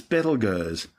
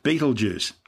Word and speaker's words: Betelgeuse: It's currently referred to by astronomers as Betelgeuse, which has been commonly corrupted to Betelgeuse. Don't say it three times. Both names are actually tortured mispronunciations of its original Arabic name, Ibtalyaza, meaning the hand of the big man of course being Orion the Hunter.